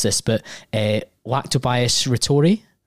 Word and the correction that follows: Lactobacillus reuteri: Lactobacillus reuteri.